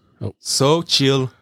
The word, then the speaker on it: Xochitl: That's Xochitl Gomez. So the T is silent.